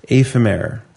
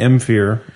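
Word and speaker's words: Éphémère: The beer is seasonal, after all, and is pronounced ephemere, not emphir or zamphir.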